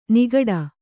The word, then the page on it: ನಗಾಡು: nagāḍu